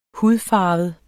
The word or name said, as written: hudfarvet